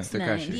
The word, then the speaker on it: Tekashi: Tekashi? That's all? – Yeah, Tekashi.